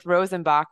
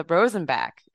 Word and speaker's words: Rosenbach: I went with Rosenbach, which is, of course, the more Germanic pronunciation, but Alex kindly told me it's the Rosenbach.